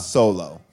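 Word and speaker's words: Seoul-Oh: His name is, it's spelled S-E-O-U-L-O. Han Solo. But that's how it's supposed to be pronounced.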